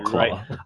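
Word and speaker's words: Klaue: Okay, it is Claw because he has a claw hand. That makes sense. – It's K-L-A-U-E. – Yeah, it's spelled different. Claw.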